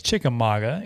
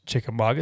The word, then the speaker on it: Chickamauga: Well, it's Chickamauga. Anybody who knows Chickamauga, it's Chickamauga, not Chickamauga.